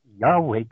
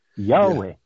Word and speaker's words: Yahweh: there's no way that the name Yahweh can be pronounced any other way than Yahweh.